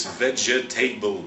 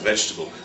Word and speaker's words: vegetable: It looks as if it's vegetable, but it's pronounced vegetable.